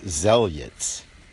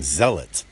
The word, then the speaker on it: zealots: Now, if you can't see how this story correlates to many other stories of its sort in regards to religious zealots, I keep saying that wrong, zealots, there it is,